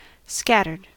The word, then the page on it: scattered: American English